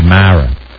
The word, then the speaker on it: Mara: You know, I'm seriously thinking it might be Mara. It's a subtle little pronunciation. It could be Mara or Mira, but it's mare, chair, scared, dare, fair, rare.